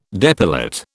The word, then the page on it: depilate: British English